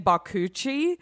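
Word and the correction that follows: bakuchiol: I think I just, cause I like that it said coochie in it, but how do you say it again? – Bakuchul. – Bakuchul.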